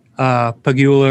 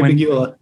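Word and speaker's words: Pegula: However, I remember a few years ago when Pagula, or however the fuck you pronounce the owner's name. – I think I actually think you got it right. Yeah. – Yeah. – Terry Pagula.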